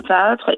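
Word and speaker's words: paotr: Bon, on continue à aller découvrir ces personnages direction Quiberon maintenant pour vous parler de celui qu'on appelle le paotre, c'est ça la prononciation est bonne ? – Oui, le paotre, exactement, alors ça signifie le gars, le petit gars.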